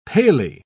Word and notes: palely: British English

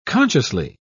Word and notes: consciously: British English

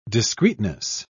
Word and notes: discreetness: British English

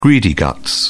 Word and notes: greedy guts: British English

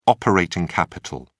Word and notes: operating capital: British English

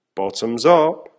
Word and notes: bottoms up!: American English

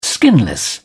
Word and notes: skinless: British English

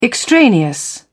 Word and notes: extraneous: British English

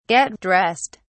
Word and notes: get dressed: British English

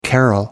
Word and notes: carol: American English

